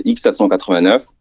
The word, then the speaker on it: X789: Donc c'est X789 ou prononcer 10-789 pour le ouais. clin d'œil à l'histoire de France.